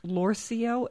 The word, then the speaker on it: Lorcio: So that's Lorcio. It looks like it's spelled or is pronounced Lorcio, L-O-R-C-I-O. CIO State Street.